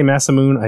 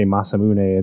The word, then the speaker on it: Masamune: I used to say Masamune. I think a lot of Americans and Westerners did. But once we got voice acting, we heard them say Masamune. It makes more sense with the Japanese pronunciation.